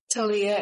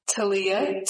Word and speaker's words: Taleah: Talia Hernandez. – Can you please spell that for the record? – Talia, T-A-L-E-A-H, Hernandez, H-E-R-N-A-N-D-E-Z.